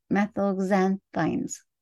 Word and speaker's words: methylxanthines: Methoxanthines? Okay, well, I can spell it for you. Methylxanthines. Methylxanthines.